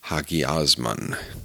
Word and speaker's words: hagiosmon: The word is pronounced hagiosmon, and that mun ending is the key. Why? Those three little letters, M-O-N, mun, describe holiness as a result, an effect, not a cause.